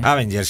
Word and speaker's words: Avenger: Avenger,